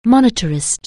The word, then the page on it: monetarist: anglais